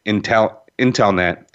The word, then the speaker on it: Intellenet: So it's, I'm going to say it again because we pronounce it IntelNet, but because of some trademark issues a long time ago, we had to kind of do things a little different.